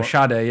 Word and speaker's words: Sade: Oh, Sade, yeah, that's it. – Yeah, Sade. – Nope. Yep. I got a wrinkle in my brain for learning that today. I was always like, oh, Sade, yeah.